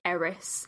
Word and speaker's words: Eris: Eris. It's spelled E-R-I-S. And it was discovered in 2005, basically. – Oh, Eris. – Wow. – Ah, Eris.